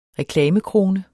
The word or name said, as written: reklamekrone